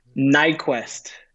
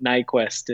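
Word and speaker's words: Nyquvest: And the answer is Nyquist. – Nyquist. – Yeah, the sneaky silent V in there always throws people for a loop. But Nyquist is how I say it anyways.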